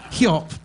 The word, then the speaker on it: Hjop: And then H-J-O-P is the track Hjop. So good luck getting your voice control speakers to play that. Hjop by Norse.